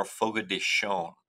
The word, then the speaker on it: Fogo de Chão: Your Portuguese a little bit more fogo de chão what oh, they shown, but you're getting there.